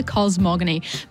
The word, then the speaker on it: cosmogony: cellular cosmogony. – Yes, well done. Cellular cosmogony.